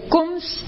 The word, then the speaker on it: Coombs: Bueno, Cums. – Sí. – Cums. – Sí. – Se escribe C-O-O-M-B-S.